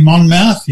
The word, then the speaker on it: Monmouth: Did I say that last? How do you, how do you say his name? – Humphrey Monmouth.